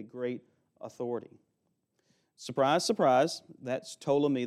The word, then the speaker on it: Ptolemy: And in the south, their rulers went by the name of Ptolemy. It starts with a silent P like pterodactyl.